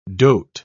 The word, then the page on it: doat: British English